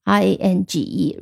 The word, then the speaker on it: range: r a n g e rain 啊，这个是 a 读成 a rain，然后后面的 g e 发成 j range range 啊，名词也可以用，动词也可以用。